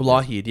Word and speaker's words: Lougheed: They talk about Lougheed. Did I say that right? – Oh, Lougheed.